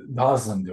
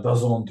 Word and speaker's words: DAZN: DAZN nasıl okunuyor bilmiyorum. – Aa, e, Dazın diyorlar, Dazon diyorlar. Dazon. – Dazon işte, diyor.